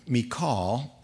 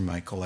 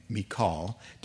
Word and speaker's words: Michal: But Michal, and that's the way we pronounce that name. Everybody calls her Michael. I told you that, but we're going to call her by her real Hebrew name, Michal, David's wife, warned him, "If you don't run for your life tonight, tomorrow you'll be killed."